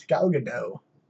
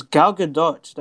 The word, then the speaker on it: Gal Gadot: Your celebrity crush, at least say her name right. It's Gal Gadot. – It's Gal Gadot. That's how you pronounce her name.